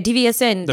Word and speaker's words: DVSN: Like DVSN. Did I say the name right? Yeah, DVSN.